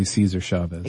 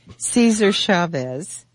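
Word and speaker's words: César Chávez: It's actually Cesar Chavez. – Excuse me. Cesar Chavez. You don't pronounce it Cesar? – No, it's always been, ever since he came to prominence as a union leader. – It's Cesar. – Cesar Chavez.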